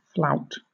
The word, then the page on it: flout: British English